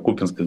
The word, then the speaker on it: Купинске: обращаю внимание, что Купинске надо ударение делать на первом слоге, чего бы никогда не узнали, если бы героическое, не героическое поведение российских войск на минувшей неделе.